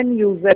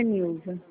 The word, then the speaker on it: Nilsa: Mas a pronúncia é Nilza, é com S mesmo, mas é Nilza.